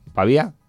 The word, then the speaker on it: Pavía: ¿Cómo se dice, Tania? ¿Pavía? Vale.